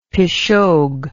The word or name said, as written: pishogue